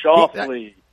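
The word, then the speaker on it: Schauffele: Charlie and I have been trying to pronounce Fander Sluislai or that would Shoffley.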